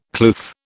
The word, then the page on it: kloof: British English